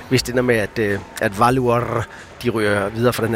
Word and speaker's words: Valur: Hvordan er det med, du udtaler det? – Valur. – Valur fra Reykjavik. Det er nemlig rigtigt.